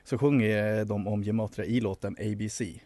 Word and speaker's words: gematria: de tror starkt på gemat gematria tror jag det uttalas. – Gematria.